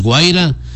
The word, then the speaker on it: Guaira: porque una cosa es Guaira y otra, me decía que no estoy pronunciando bien, una cosa es Guaira y otra cosa es Guajira, así es, ¿no? Guaira una cosa y Guajira es otra cosa.